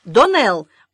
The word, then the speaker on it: Доннелл: Итак, Доннелл, ударение на второй слог, и Сен-Клер ни в коем случае не Джекоб.